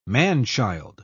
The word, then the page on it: man-child: British English